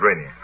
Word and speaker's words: Rainier: Rainier. How do you pronounce that in America? – Mount Rainier, good.